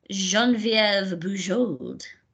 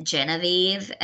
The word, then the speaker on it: Geneviève Bujold: And that's the proper way to pronounce her name, the French way. – Oh. – Geneviève Bujold. If you're American, you might pronounce it Genevieve, and that's just a terrible butchering of that name.